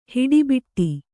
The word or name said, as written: ಹುಡಿಬಡಿ